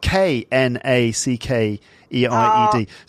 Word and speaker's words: knackered: Right, next is this is the fifth and final one from the British English list, and this is the word knackered, and it's spelled K N A C K E R E D. So you've got a silent K, knackered, and it's an adjective.